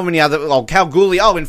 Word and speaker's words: Kalgoorlie: But anyway, they say it wrong. They say like Kalgoorlie. The way they say the word, it is not how you pronounce it. It's Kalgoorlie, everybody.